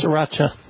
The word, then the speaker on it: Sriracha: Buffalo Wild Wings Sauce Lab, introducing Sriracha Sizzle. Is that how you pronounce it? S-R-I? – Sriracha.